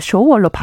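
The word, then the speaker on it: 쇼월 (首尔): 근데 지금은 이것을 저희가 쇼월이라고 부릅니다. 네. 굉장히 흡사한 발음인데 예전에는 이걸 중국식으로 한청, 한성이라고 불렀습니다. 아. 그래서 저희가 이거를 쇼월로 바꾸는 노력을 굉장히 했고요.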